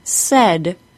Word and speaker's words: said: Here are all four say and saying with a long A says, and said with a short E.